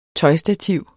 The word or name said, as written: tøjstativ